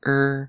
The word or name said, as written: rr